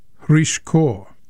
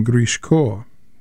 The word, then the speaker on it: Hrishko: He's Ukrainian, so we use the Ukrainian G, a H sound, instead of the Russian hard G. But I'm sure at times tonight I'll call him Grishko. That was what he was called when he sang in Russia.